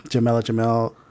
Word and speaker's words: Jamila Jamil: Kristen Bell, William Jackson Harper, Jamil. I feel like I said that wrong. – Jamila Jamil, D.R.C.